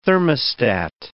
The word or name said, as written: thermostat